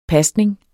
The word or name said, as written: pasning